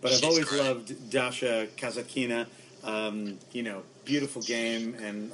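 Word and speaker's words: Kasatkina: Kazatkina. – Who? – Kazatkina.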